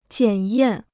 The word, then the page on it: 检验: jiǎn yàn